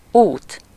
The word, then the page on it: voie: [vwa]; /vwɛ/; /vwɑ/